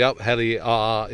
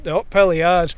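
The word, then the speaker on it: Up Helly Aa: the Upheli Ar, if that's the correct pronunciation. – Yeah, that's very good. – Which takes place at the end of January, doesn't it? – Yeah, the Upheli Ar, that's a big thing in Shetland.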